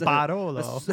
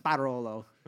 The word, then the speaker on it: Barolo: Well, the Italians always enunciate the first symbol hard, or the first syllable hard. – Is it the first syllable? – Yeah. – Okay. Yeah, Subaru. Yeah, I remember that. – Subaru. – Subaru, yeah. – It's not a Subaru. It's Barolo.